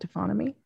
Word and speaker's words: taphonomy: What would you say the future of your profession of, and remind me how to pronounce it correctly, taphonomy? Is it taphonomy? – Perfect.